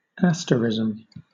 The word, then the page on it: asterism: British English